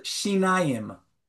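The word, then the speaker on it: shinayim (שיניים): Your teeth are called shinayim.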